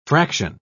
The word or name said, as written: fraction